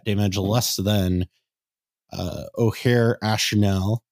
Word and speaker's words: Ojer Axonil: Ojer Axonil is how you say it.